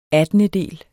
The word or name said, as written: attendedel